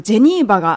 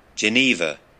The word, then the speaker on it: Geneva: えっ、と、大学施設の名前はですね、ジェニーバ・ユニバーシティ・ホスピタルズという名前でして、えっと、ジェニーバが英語のジュネーブの発音になります。